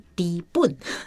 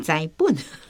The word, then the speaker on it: 知本: 在最早讲那个一字多音的时候，就是专有名词，我们基本上是用文言音、读音，但是也是要看地方，所以知本是底本，不是栽本，你低不低呀？ – 对，低不低？